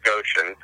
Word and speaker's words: Goshen: Probably because my life in, and it's pronounced Goshen, my life in Goshen was so narrow.